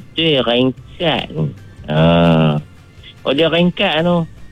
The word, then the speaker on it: rengkat: Seterusnya, Ringkat. Ejaan dia R-E-N-G-K-A-T. Atau dia ada satu lagi, dia tak sebut ring. Mm-mm. Dia sebut ring. Ringkat. Ejaan dia R-I-N-G-K-A-T.